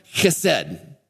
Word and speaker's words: chesed: It's pronounced chesed.